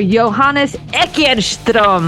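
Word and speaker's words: Johannes Eckerström: Avatar singer Johannes Ekenström. I'm trying to say his name properly. How did I do that time, Johannes? – That was lovely, I think.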